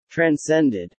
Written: transcended

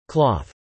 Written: cloth

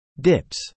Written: dips

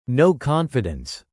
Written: no-confidence

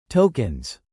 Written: tokens

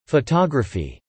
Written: photography

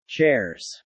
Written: chairs

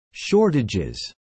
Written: shortages

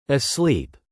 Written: asleep

